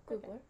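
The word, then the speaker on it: Gubler: Yeah, Googler.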